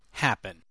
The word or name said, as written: happen